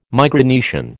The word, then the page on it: Micronesian: American English